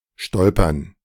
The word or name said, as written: stolpern